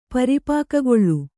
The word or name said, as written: ಪರಿಪಾಕಗೊಳ್ಳು